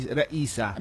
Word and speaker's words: Raisa: Pero actually ang pag-pronounce ko na yan sa ngalan ni Mayor Raisa, si Raisa. – Raisa. – Raisa, oo.